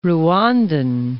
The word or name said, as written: Rwandan